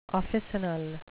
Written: officinal

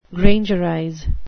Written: grangerize

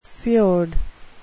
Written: fjord

